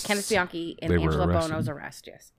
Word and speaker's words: Bianchi: Awkward Key? No, Bianchi.